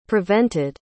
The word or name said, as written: prevented